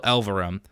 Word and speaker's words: Elverum: So Mount Eerie is the project that some people might be more familiar with as of recent, but the artist Phil Elverum, I think that's how you pronounce his last name. Yes, Phil Elverum previously was releasing music under the moniker of The Microphones.